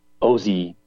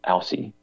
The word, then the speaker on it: Aussie: The average Aussie bloke, and this is pronounced Aussie, not Aussie. Aussie, it's pronounced Aussie bloke.